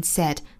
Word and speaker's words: said: Said 这个单词我们注意不要把它读成了 said, 不要看到单词的写法是 ai, 我们就把它发成了 i 的音。